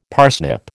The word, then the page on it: parsnip: American English